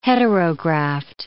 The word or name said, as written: heterograft